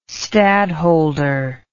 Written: stadholder

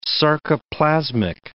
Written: sarcoplasmic

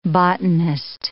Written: botanist

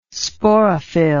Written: sporophyll

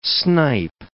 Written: snipe

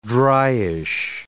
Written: dryish

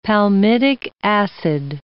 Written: palmitic acid

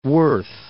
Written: Fort Worth

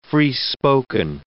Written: free-spoken

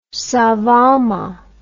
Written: savama

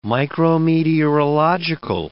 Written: micrometeorological

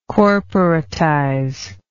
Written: corporatize

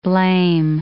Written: blame

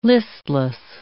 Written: listless

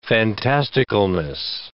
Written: fantasticalness

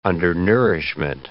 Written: undernourishment

